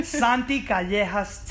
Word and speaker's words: Santi Callejas: Santi Callejas 10. – Can you spell that, please? – Yeah, S-A-N-T-I-C-A-L-L-E-J-A-S 10.